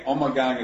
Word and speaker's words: Ommegang: Both are okay. Ommegang is closer to the Flemish pronunciation, but a lot of people call it Ommegang too.